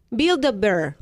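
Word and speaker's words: Build-A-Bear: ¿Cómo es? – Buildabear. – Ok. – ¿Verdad? Ah. Lo dije bien, lo dije bien.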